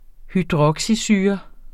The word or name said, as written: hydroxysyre